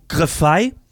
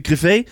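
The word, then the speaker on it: Griffais: Det er altså fordi, at Valves egen koder, Pierre-Luc Griffey, eller undskyld, Griffey,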